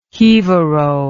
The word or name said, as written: jivaro